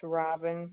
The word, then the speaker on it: Robin: It's Robin Roenpegel. – R-O-B-I-N? – Yeah.